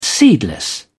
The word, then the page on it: seedless: British English